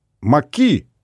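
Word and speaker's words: maquis: Маки с ударением на последний слог.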